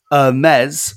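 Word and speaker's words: Hermès: And then he goes, Hermes, because French, is the clothing brand.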